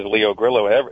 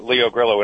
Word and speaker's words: Leo Grillo: Does it have the, you know, in Southern California here, if you put two L's together, they're silent. – When I first came out here, I said, my name is Leo Grillo. Leo Grillo. And when I spell it, they say, Leo Carrillo.